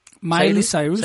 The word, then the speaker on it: Miley Cyrus: Porque eh, Miley Cyrus, ¿lo dije bien? – Miley Cyrus,